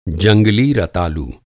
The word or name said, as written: रतालू